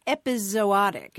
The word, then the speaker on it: epizootic: That's so interesting that your grandmother picked up on that because, you know, at least as early as the mid 1700s, we see this word that's pronounced epizootic. It's the word you're talking about E P I Z O O T I C.